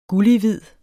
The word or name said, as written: gullighvid